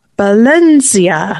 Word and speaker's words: Valencia: So they pronounce it with a B for starters. And then instead of the S sound, it's a Z sound at the end. Valencia.